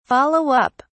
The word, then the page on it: follow up: British English